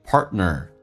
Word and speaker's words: partner: 那么下面一个单词很多人听的也许不太习惯，那么在口语化当中 partner 那个 t 通常是不发音的，那么到这边气流就阻塞一下，就变成 partner 所以就变成 to your partner 所以这个对听力有很大的一个影响。